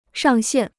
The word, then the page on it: 上限: shàng xiàn